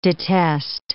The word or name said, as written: detest